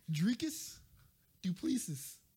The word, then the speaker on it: Dricus du Plessis: Dricus du Plessis.